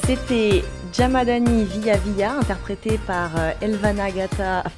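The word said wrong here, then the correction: Gjata: Alors, euh, c'est Elvana Jata, et Flori Mumayesi, parce que le J c'est c'est les Y, Mumayesi, et Mentor Haziri.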